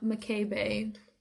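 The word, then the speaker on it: Mikaebae: Hello, if you are on TikTok, please follow me at McKay Bay.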